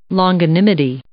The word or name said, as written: longanimity